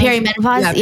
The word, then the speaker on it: perimenopause: Because with my blood work, she was like, and I'm going to say this wrong, is it perimenopause? – Perimenopause, yeah. Perimenopause.